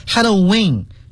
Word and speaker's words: Halloween: Halloween。 – Halloween, Halloween。其实有好多人他的发音不一样。其实一样的，你说 Halloween, 就是美国很普遍的一种民间的发音方式，但是在官方呀，官方就把它界定为 Halloween, 其实读作 Halloween, Halloween 都是可以的。